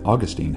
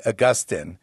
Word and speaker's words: Augustine: How do you pronounce the guy's name? There's the Tin Men, they say Augustine. And then there are the teens, they say Augustine. And that's how I tend to pronounce it. The Augustine camp, they look at Augustine's name originally in Latin, Augustinus. Augustinus. And so what we do is we drop the us and we sort of anglicize the middle vowel.